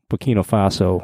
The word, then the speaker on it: Burkina Faso: And just the colors on the Burkina Faso, I'm not even sure if I'm pronouncing that country name correctly, but. – Yep, that's right.